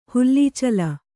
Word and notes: ಹುಲ್ಲೀಚಲ: hullīcala

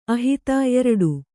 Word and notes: ಆಹಿತ: āhita

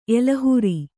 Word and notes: ಎಲೆಹುರಿ: elehuri

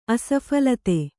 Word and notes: ಆಸ್ಫಾಲಿತ: āsphālita

